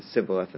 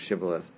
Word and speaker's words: Shibboleth: And it's a question of how they pronounce the letter shin, whether they pronounce it with an SH or an S sound. So the Gileadites pronounce it as a shin, and the Ephraimites pronounce it as a sin. So they would say Sibboleth instead of Shibboleth.